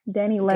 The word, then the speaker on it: Dani Leigh: Is it right? Dani Leigh? – Yeah. Leigh? Dani Leigh. – Dani Leigh.